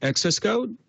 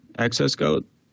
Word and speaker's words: xs:code: We, we pronounce yeah, we pronounce it access code, like access code.